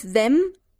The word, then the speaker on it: them: Mm, with them. With them. – Yes, that's right. Them. With them. Cuidado, la M en them es con boca cerrada.